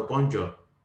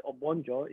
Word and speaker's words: Obonjo: So it's a racist term, Obongo, rather than Obonjo. And it took them a while before they could pronounce my name properly. It took them a while. – Wow. And it's not hard, Obonjo, you know.